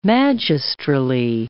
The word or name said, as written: magistrally